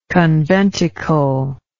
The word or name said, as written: conventicle